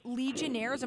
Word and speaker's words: Legionnaires: Legionnaires, am I saying it correctly? – Yes.